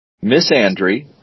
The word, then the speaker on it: misandry: All right, there's my word is pronounced two ways, depending upon where you live. Misandry or misandry.